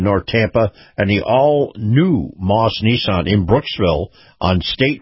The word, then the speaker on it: Maus: Army, brought to you by our friends at Moss Nissan. Moss is spelled M-A-U-S.